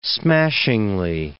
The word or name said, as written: smashingly